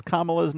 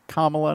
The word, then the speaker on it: Kamala: The other side, like, they can't pronounce Kamala's name, which is Kamala, not Kamala.